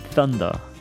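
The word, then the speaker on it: Thunder: 왠지 혀를 꽉 깨물고 뜨발음 해야 될 것 같은 그 썬더 예, 들어봤습니다.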